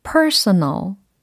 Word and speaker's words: personal: personal, personal, personal 이라는 단어예요. personal이 아니라 personal 이라는 거거든요. 그래서 r 사운드도 끼어 있고 l로 끝납니다.